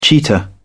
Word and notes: cheetah: British English

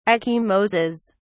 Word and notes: ecchymoses: British English

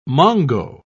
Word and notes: mongo: British English

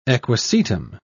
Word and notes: equisetum: British English

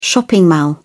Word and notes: shopping mall: British English

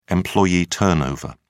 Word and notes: employee turnover: British English

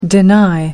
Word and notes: deny: American English

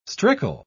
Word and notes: strickle: British English

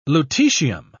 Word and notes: lutetium: British English

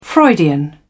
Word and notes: Freudian: British English

